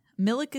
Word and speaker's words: Milek: Milek is how you pronounce it. I asked him for confirmation.